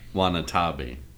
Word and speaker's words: Watanabe: Watanabe. – Watanabe? What did I say? Wasabi? Watanabe. Oh.